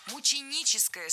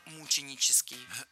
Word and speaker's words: мученическая: Ударение здесь должно падать на первый слог – мученическая смерть. Вспоминаем однокоренное существительное «мученик», от которого образовано наше прилагательное. Ну и ориентируемся именно на него – мученик, мученический.